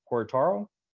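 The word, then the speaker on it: Querétaro: Got to put the emphasis on the E because it's got the accent. – Guaitaro.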